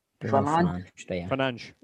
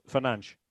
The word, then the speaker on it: Fernandes: Fernandes. – So it's like a little soft SH on the end. It's actually quite easy, Fernandes. Go on, say it.